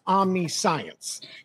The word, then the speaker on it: omniscience: It's linguistically speaking, and I don't know much about this life, but I know that linguistically speaking, it should be pronounced omniscience.